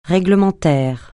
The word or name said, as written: réglementaire